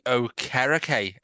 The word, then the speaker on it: Okereke: of uh, Bobby Okereke, as he pronounces it.